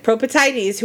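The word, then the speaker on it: Propoetides: Propertides? – Perfect. Propertides, who are the daughters of Propertis from the city of...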